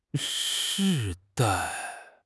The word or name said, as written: 世代